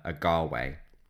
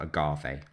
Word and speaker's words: Agave: So he includes the women of Thebes in driving up this frenzy, and which includes his aunts and, crucially, Pentheus's mother, Agave. It looks like it's spelled agave, like the tequila, but it's agave because it's Greek.